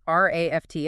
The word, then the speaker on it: RAFT: And for anyone diving in with us, RAFT, and that's pronounced just like the word raft, it's an acronym.